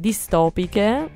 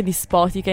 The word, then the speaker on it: distopiche: oggi parliamo di guerre distopiche, l'ho detto giusto, hai visto? – Sì, brava. – Sì, perché mi viene sempre dispotiche invece di distopiche.